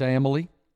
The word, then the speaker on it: Amalie: Amalie Arena, I've just learned after being there for 4 consecutive days how to pronounce it. – And Amalie. – It's Amalie. Like family, they tell me. It's like family.